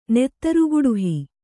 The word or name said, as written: ನೆತ್ತರುಗುಡಿಹಿ